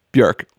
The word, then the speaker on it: Björk: That's what she says. Björk. Rhymes with jerk.